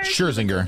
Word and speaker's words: Scherzinger: Lil Wayne, T-Pain, Oh, I don't know her last, how to, like, pronounce her last name, but Nicole Scherzinger. – Scherzinger.